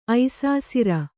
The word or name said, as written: ಐಸಾಸಿರ